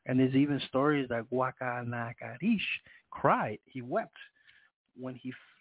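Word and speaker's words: Guacanagarix: And then the other cacique name, let me spell it out, G-U-A-C-A-N-A-G-A-R, accent on the I-X, Guacanagarish.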